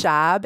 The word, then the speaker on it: Schaub: It's Schaub. It rhymes with Bob.